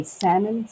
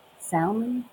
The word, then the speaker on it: salmon: I say salmon. Some people pronounce the L, salmon, which. I, Ooh. I know it's there, but yeah, I don't say it.